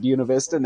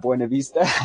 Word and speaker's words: Buena Vista: And you know I'm from Buena Vista because I pronounce it Buena Vista, not Buena Vista. – Puny, as they sometimes say.